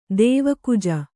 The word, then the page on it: ದೇವಕುಜ: dēva kuja